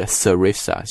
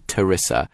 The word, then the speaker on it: Theresa: 一开头的主语 Prime Minister Theresa May 一直听新闻的同学应该不陌生了，就是英国首相特蕾莎梅。那么很多同学说，有的时候听 BBC 听这个 Theresa May 她的名字的时候会听成 Theresa May。对的，这个名字其实英式英语有两种读法，一个是 Theresa，注意在读 Theresa 的时候这个 H 音不发音啊，不是 Theresa，是 Theresa。